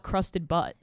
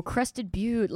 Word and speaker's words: Crested Butte: How do you pronounce it? – Crested Butte. – Crested Butte. I was going to call it Crusted Butt.